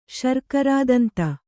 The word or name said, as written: ಶರ್ಕರಾದಂತ